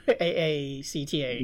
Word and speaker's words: AACTA: AACTA. – Yeah. but pronounced actor, like people who act.